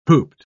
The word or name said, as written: hooped